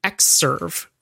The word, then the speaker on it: Xserve: But as you will note, it's pronounced X, and it's spelled with a capital X and lowercase serve. And they were not the 10 serve, they were the X serve,